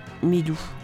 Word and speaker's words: Milu: M-I-L-U, mais comme le U se prononce OU, ça faisait Milou.